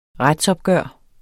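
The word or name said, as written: retsopgør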